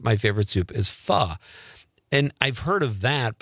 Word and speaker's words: pho: Are you saying that right, Conrad? Is it pho? – It's pho. – Pho.